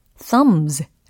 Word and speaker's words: thumbs: thumb 이게 기본형이잖아요. 근데 엄지손가락 두 개라고 했으니까 s 복수형 어미가 붙은 겁니다. 근데 thumb, thumb 이게 기본형 발음이에요. 그리고 복수형이 붙어도 thumbs, thumbs 라고 하셔야지 thumbs, thumbs 이렇게 하시면 안 된다라는 게 팁이 되겠습니다. 어, 철자에는 남아있는 b이지만 소리는 묵음이다라는 게 힌트가 되겠죠.